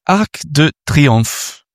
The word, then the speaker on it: Arc de Triomphe: So I'm standing on top of the Arc de Triomphe with a French lady. Can I ask you something? It's a really easy question to start. How's my pronunciation of Arc de Triomphe? – Well, very well.